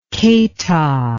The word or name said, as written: keita